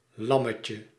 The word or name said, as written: agneau